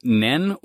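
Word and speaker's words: nen (念): So in Japanese, though, this is pronounced nen or zen, depending on how it's used in character compounds. – Mm-hmm. – That's the onyomi reading, aka the Chinese influenced reading.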